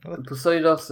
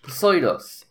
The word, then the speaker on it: Pusoy Dos: Poseidos is... – spell it. – P-U-S-O-Y-D-O-S. Poseidos.